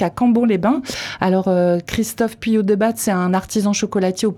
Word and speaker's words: Puyodebat: Puyot de Batte à Cap-Breton. Bonjour Laurent. – Bonjour. – Je suis pas loin c'est bien. – Puyot de batte.